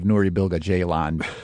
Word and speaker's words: Nuri Bilge Ceylan: Let me guess, that tattoo, it's going to be just the name of Nuri Bilge Ceylan.